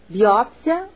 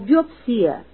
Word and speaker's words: biópsia: Os seguintes vocábulos admitem dupla tonicidade: acróbata ou acrobata, biótipo ou biotipo, hieróglifo ou hieroglifo, oceânia ou oceania, xerox ou xerox. Recomenda-se a pronúncia xerox, por ser mais usual. Autópsia ou autopsia, biópsia ou biopsia, homilia ou homilia, projétil ou projetil, urease ou urease.